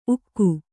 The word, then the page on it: ಉಕ್ಕು: ukku